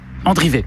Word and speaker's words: Andrivet: Parce que c'est A-N-D-R-I-V-E-T. Donc Andrivé ou Andrivette. Mais je pense que c'est Andrivé. – Andrivé.